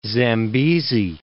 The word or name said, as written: Zambesi